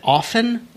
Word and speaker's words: often: And he has a whole article recently put out that's asking the question, how do you pronounce the word spelled O-F-T-E-N? Is it often or often? – Right. – Do you enunciate the T or not?